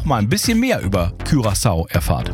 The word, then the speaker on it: Curaçao: Und wir haben schon mal rausgefunden, es heißt Curaçao, nicht Curaçao. – Richtig. – Auch wenn das der ein oder andere vielleicht denken mag, Curaçao ist die richtige Aussprache.